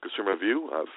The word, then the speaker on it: Consumervue: Consumervue, the E is silent. That's Consumervue, Consumervue.